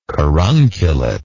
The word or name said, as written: carunculate